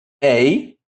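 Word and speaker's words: Ei (εἰ): Se pronuncia ei. Então, veja que esse epsilon, ele muda para o som de e, por causa do ditongo. Então, fica como, por exemplo, na palavra lei. Então, não fica ei o som. O som fica de ei. Então, esse aqui há uma mudança na pronúncia do epsilon, que de aberto passa a se tornar fechado.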